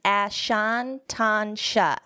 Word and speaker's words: Anatasha: It's Anatasha.